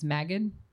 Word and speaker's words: Magid: And has been recognized with the Penn, and I never know how to say this, it's Magid. It's a soft G. Yeah. Penn Magid Award for Excellence in Editing and the AWP Prize for Best Small Press for One Story.